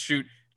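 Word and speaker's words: Schutt: I'm here to update that we have learned since Thursday that his name is pronounced Shoot.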